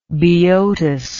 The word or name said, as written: Boeotus